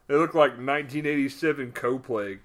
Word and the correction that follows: cosplay: Cosplay. Is that how you say it? Cosplay? – Yeah. Yeah.